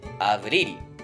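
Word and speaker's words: abril: 1 月エネロ、 2 月フェブレーロ、 3 月マルソ、 4 月アブリリ、ここはリの後ろの方にアクセントがきますね。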